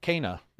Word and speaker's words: Kena: FYI, the devs on Twitter mentioned it's pronounced Kena.